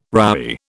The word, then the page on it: roughy: British English